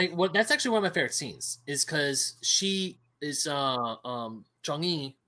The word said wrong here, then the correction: Chang'e: or Chang'e. – Chang'e.